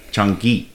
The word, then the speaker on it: Changi: I say it right? Changi. – Hard G. – Okay, Changi.